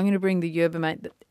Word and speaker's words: mate: I was saying yerba mate. That's the cup that the Argentinians use. It's got a very interesting shape. It's pronounced mate, and you're saying that it does taste quite bitter and unpleasant.